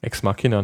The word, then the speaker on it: Ex Machina: Wie soll man das aussprechen? – Ex Machina nenne ich das.